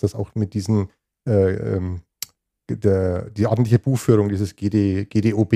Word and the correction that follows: GoBD: ich kann sie mir nicht richtig aussprechen, Grundsätze der ordentlichen Buchführung. – GOBD, glaube ich. – GOBD, genau, so, vielen Dank.